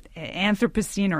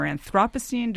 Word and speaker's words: Anthropocene: And the term Anthropocene or Anthropocene, depending on if you're a Brit or an American, they're pronounced differently,